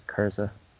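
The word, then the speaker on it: Curze: Uh, I pronounce it uh, bec- uh, that way because he's clearly German, so I'm going to pronounce it uh, as Kurza.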